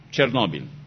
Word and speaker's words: Chernóbil: Se escribe C-H-E-R-N-O-B-I-L. Chernóbil.